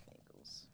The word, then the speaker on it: Bengals: I feel like I always pronounce that wrong. – No, I think it's Bengals.